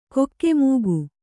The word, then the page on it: ಕಕಮಕ: kannada